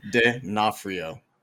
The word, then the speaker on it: D'Onofrio: Vincent D'Onofrio. – I thought you wrote it out phonetically or whatever. – I tried, but D'Onofrio.